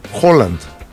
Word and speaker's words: Haaland: Bueno, y por otro lado, Erling Haaland, que luego ya me enteré porque entrevistaron a un noruego, ¿cómo se pronuncia Haaland? Ves que tiene doble A, ¿no? – ¿Haaland? – No, Haaland. – Haaland. – Haaland. Ellos es Erling Haaland. Bueno, Haaland. Para nosotros es Haaland.